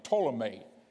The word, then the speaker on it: Ptolemy: And that was Egypt down here, and it was taken over by a man by the name of Ptolemy, spelt with a P, P-T-O-L-E-M-Y, but pronounced M-A-Y, Ptolemy, the Ptolemy dynasty.